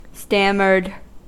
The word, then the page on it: stammered: American English